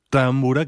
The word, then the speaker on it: támbura: Y, por ejemplo, la primera támbura, que más bien así lo pronunciarían los húngaros, es la de Aaron Erdős, que en parte de esta pieza también se ocupa del tambor tapán.